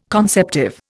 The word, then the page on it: conceptive: Indian